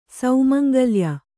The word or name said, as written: ಸೌಮಂಗಲ್ಯ